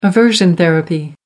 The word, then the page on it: aversion therapy: American English